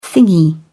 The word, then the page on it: thingy: British English